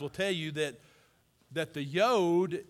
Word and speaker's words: yod: It's pronounced yud or yod. We're going to say yod. Spell Y-U-D.